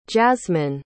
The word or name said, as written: jazmyn